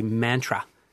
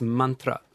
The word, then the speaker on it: mantra: So all the R's in Sanskrit are like a tap. You know, we say mantra in Australia. – Don't we? – Actually, it's mantra. The correct pronunciation is very important with Sanskrit because of the nature of what the language is.